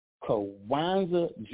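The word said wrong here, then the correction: Jawanza Kunjufu: Juwanza Kanjufu.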